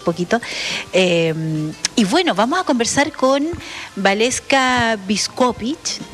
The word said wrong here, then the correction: Biskupić: No, lo pronuncié pésimo. Mira, esto lo ensayé hartas veces junto a Valeska. Es Biskupić. Kupovich. Ahí sí. Perfecto. Lo dije bien.